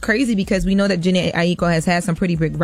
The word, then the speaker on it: Jhené Aiko: So, Jhené Aiko. Told y'all I said it right. – Yes, you did.